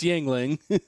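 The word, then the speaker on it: Yuengling: I'm probably mispronouncing it, so I could be a racist. – No, you're not mispronouncing it. It's Yingling.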